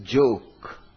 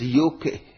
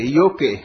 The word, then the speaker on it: Joke: The spelling says joke. But Dutch people are strange. They pronounce it as yoke. They destroy the whole beauty of it. Okay, yoke.